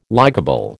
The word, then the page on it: likeable: American English